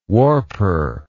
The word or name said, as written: warper